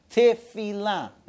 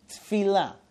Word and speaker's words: Tefila: And it's Shomea Tefila, not Tfila. Tfila means unimportant things. Tefila means prayer. It's two different things. So we have to pronounce it correctly.